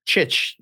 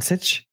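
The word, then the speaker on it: Dončić: What do they say? They say Doncic, Doncic, Sitch with an S. Sitch. It's Chich.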